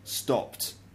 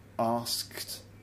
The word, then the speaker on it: asked: So whereas I would say stopped, you might find like a Brazilian or a Spanish person saying stopped. I would say, for example, asked, and you might say asked, for example.